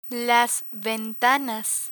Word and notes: ventanas: Spanish

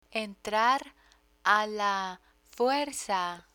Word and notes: entrar a la fuerza: Spanish

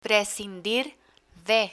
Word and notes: prescindir de: Spanish

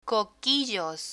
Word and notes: coquillos: Spanish

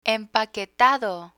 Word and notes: empaquetado: Spanish

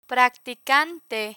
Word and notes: practicante: Spanish

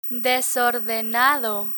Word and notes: desordenado: Spanish

